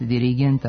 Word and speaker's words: dirigent: Ispravno je reći samo asistent, dirigent, absolvent, element. Ovako akcentovanje rezultat je pravila da posljednji slog u reči ne može biti naglašen.